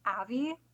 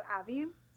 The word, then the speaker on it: Avi: You pronounce her name Avi because it rhymes with Savvy. Yeah, so Avi.